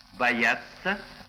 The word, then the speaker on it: боятся: Глагольные окончания третьего лица множественного числа «тся», «тс», «я» и окончание неопределенного наклонения «т» мягкий знак «ся» произносится одинаково, как «ца». «Боятся», «они боятся» и «не надо бояться» произносится совершенно одинаково.